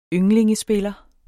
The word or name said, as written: ynglingespiller